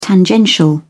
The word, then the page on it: tangential: British English